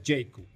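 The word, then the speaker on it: Jaecoo: JQ se escribe Jaeco. – Con doble O al final. Jaeco pero se pronuncia Jacob.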